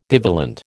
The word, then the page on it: divalent: American English